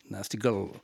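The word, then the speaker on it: Nasty Gal: Nasty Girl qui s'écrit dans le jargon, dans le slang américain, c'est-à-dire G-A-L, Nasty Girl. Mais c'est bien sûr Nasty Girl.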